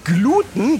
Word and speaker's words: Gluten: Und mir wurde noch gesagt, dass man das Wort auch oft auf dem U betont, weil Gluten das lateinische Wort für Leim ist. Und aus dem Lateinischen ins Deutsche übertragene Wörter haben häufig ihre Betonung auf dem langen Vokal. Aber im Prinzip ist beides richtig, je nachdem, wie man es mal gelernt hat.